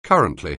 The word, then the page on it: currently: anglais